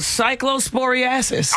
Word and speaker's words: cyclosporiasis: Yeah, but it's called cyclosporiasis.